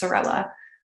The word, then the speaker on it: Saarela: It's Finnish, which is a little different spelling with all those vowels, but it's Saarela.